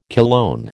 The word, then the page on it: cologne: American English